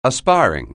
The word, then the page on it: aspiring: anglais